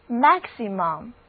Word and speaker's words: maximum: 同样的 max 的意思也是大的，所以在它的后面先加上 im，这个字母组合的意思是最，然后再加上后缀 um，那得到的单词就是 maximum。 maximum 它的意思就是最大量、最大的。字母 a 的发音是梅花 a，字母 i 的读音是 e，而字母 u 的读音是短的 a，重音第一个音节，整个单词读作 maximum。